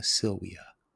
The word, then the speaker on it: Silvia: Spelt Aurea Silvia, but again, Latin, pronounced V's as W's, so Aurea Silvia.